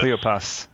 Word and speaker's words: Cleopas: Cleopas, yeah.